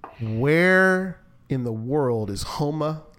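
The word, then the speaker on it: Houma: We've heard, at least I have heard a lot about HOMA, and I pronounced it correctly. – You did it. HOMA. HOMA, Louisiana.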